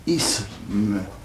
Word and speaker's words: isme: La prononciation correcte consiste à prononcer un se, un s, isme. Voyez-vous ? Maintenant, la prononciation populaire consiste à mettre un ze à la place d'un se. L'isme, non seulement populaire à Paris, mais on peut dire général dans toute la France. Isme avec un se est une prononciation savante.